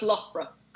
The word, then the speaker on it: Loughborough: Loughborough? – That's Loughborough.